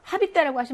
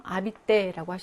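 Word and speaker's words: habiter: 이거 아시는 발음이 안 나는 철자니까 하비떼라고 하시면 안 돼요. 아비떼라고 하셔야 됩니다.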